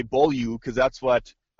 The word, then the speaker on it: Beaulieu: Apparently, he asks for it to be pronounced Beaulieu. – Beaulieu. Beaulieu. – Beaulieu. – Beaulieu. I can live with that. – Yep. So that's how I'm going to pronounce it.